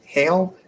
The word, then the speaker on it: Heil: And so you've got people like Lowe and I think he pronounced it Heil, H-E-I-L. – I always say Heil, but... – Heil, that sounds right.